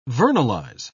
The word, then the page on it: vernalize: British English